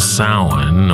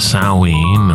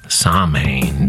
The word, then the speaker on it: Samhain: It's called Samhain or Samhain or Soin, depends on your particular Celtic pronunciation, your particular tribe. Americans sometimes say Samhain due to the way it's spelled. And due to the fact that Glenn Danzig once had a band of the same name, and because horror punk record covers rarely come with Gaelic pronunciation guides.